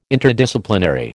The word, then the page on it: Interdisciplinary: American English